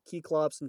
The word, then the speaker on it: Cyclops: But if we were to pronounce it correctly, it'd be like Keyclops and Keyclopes.